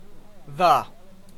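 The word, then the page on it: the: Canadian; unstressed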